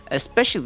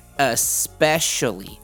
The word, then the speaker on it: especially: especially 的话，还有一个连音，那它有四个音节，里面的有一个 c，它的 c 我们所谓叫做 soft c，那这个 c 的发音是念 s 的音，所以变 especially。